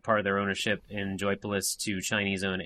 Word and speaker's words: Joypolis: I keep wanting to call it Joyopolis, but it's just Joypolis theme parks.